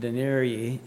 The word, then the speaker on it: denarii: When I think of taking a year's wages, Mark refers to it having been worth about 300 denarii, I believe is the way it's pronounced.